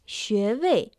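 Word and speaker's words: 穴位: Активные точки на человеческом теле на китайском языке звучит как «шуэвэй», произносится отдельно во втором и четвертом тоне.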